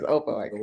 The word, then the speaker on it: Opelika: What were some of the other sports in Opelika? Is that how you say it? – Yes, Opelika.